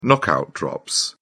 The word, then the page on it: knockout drops: British English